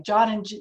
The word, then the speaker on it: Jean: Jean in French is spelled John. Anyway, John and J-E-A-N is pronounced John in French.